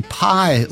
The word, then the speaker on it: Pye: By the way, The Searchers recorded for the Pye label, and that's spelled P-Y-E, and that rhymes, well, it rhymes with cry, as in, I'll cry tomorrow.